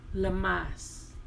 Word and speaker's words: Lammas: Oh, let me pronounce that better. Lammas.